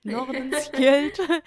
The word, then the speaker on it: Nordenskjöld: Und ich war so, ja, das wird wahrscheinlich wie ein Ö ausgesprochen. Nordenskjöld.